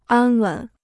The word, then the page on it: 安稳: ān wěn